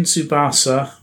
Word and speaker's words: Tsubasa: It's Captain Tsubasa, Rise of New Champions.